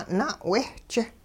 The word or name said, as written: na'wehch